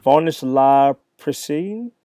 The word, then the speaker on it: Varnish La Piscine: Varnish La Piscine is how you say his name. He's French.